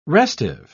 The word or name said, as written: restive